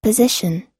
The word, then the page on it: position: American English